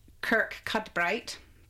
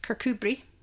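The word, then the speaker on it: Kirkcudbright: And then, of course, you've got Cooper, which is actually spelt Cowper, kind of C-U-P-A-R, Cowper, but it's actually Cooper. And the best one is how it looks written down is Kirk Cudbright, but it's actually pronounced Kirkubri.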